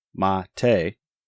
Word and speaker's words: MATE: "The name Mate, pronounced Mate, comes from Yerba Mate, a species of holly native to subtropical South America.